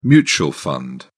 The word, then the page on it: mutual fund: British English